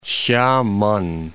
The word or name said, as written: Hsia-men